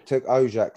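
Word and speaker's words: Ocak: They were winners 6-1 against Turk Kaji Limassol today in the London Cup. – Yeah, so, Turk Ojak, that's pronounced. – Oh, Ojak. There yep.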